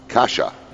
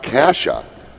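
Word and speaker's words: Caixa: The box press Caxia, pronounced Kasha. Okay, so I pronounced it wrong. Pronounced Kasha. The word means box in Portuguese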